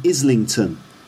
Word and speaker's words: Islington: But he says Islington, Islington, or Islington, because he doesn't pronounce the T sound, just like Islington.